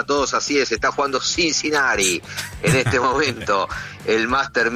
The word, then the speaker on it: Cincinnati: Creo que es a ver cómo lo digo, Cincinnati. – Sí, no es el Cincinnati, esa T no es como la pronunciamos Cincinnati. Nosotros en castellano.